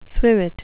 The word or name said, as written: swivet